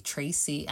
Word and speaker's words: Tracy: I know everybody calls me Tracy, and kind of funny that goes both ways because my name is spelled T R A C Y.